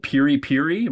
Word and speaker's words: piri piri: You mentioned in the piece there's something called piri piri. Am I saying it right? – Right. Okay. Yes.